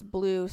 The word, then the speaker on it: Bleu: And it's blue, spelled like B-L-E-U, Like fancy. – Yeah, like fancy. Yes. – or like the cheese.